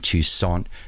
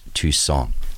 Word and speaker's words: Toussaint: And in case you were wondering, I'm pronouncing his name Toussaint because that's the way he pronounced it. It's spelt T-O-U-S-S-A-I-N-T, but because of the French influence in New Orleans, it's pronounced Toussaint.